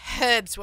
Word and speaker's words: herbs: It's herbs where I come from. Uh-huh. We pronounce our H's.